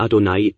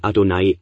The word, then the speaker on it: Adonai: Und dann unser Herr in Kleinbuchstaben, wobei das erste Adonai und das andere Adonai ausgesprochen wird.